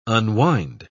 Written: unwind

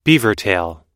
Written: beavertail

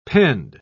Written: pend

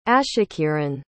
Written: ashakiran

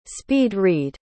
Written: speed-read

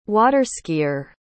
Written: water-skier

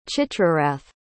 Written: chitrarath

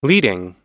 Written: leading

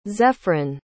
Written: zephryn

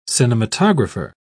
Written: cinematographer

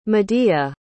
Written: medea

